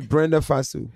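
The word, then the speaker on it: Brenda Fassie: Am I saying her name right? South African. The one that she passed? I think she passed away, right? – I don't know. – Oh, Burkina Faso. I used to go on stage to that song. Me and Easy Now. I love that song. I'm not saying her name right. – It's Brenda Fasu.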